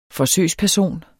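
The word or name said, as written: forsøgsperson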